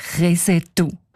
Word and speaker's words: Krisental: Der Ueli Sagesser schreibt, ein älterer ortskundiger Mann habe den Namen allerdings nicht als Krisental ausgesprochen, sondern als Resetu.